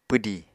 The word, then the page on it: pwdi: apatani